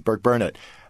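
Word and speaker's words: Burkburnett: Oh, well, very well, good pronunciation. – Yeah, good. – most people mess that up. Yes, yeah, it's Burke Burnett.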